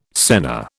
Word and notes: senna: British English

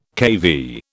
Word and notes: kV: British English

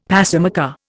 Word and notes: pacemaker: Indian

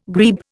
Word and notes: grebe: Indian

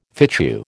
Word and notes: fitchew: American English

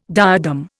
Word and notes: diadem: Indian

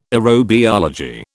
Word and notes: aerobiology: British English

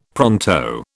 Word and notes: pronto: British English

